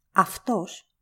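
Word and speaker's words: Aftos (αυτός): A and U make af. This rule is ignored in English, mostly because the Greek words that came here were pronounced in Latin. Aftos, or eaftos, in ancient Greek and modern Greek means the self.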